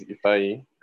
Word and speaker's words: Ifeanyi: Well, my name is Ifeanyi.